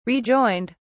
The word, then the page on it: rejoined: British English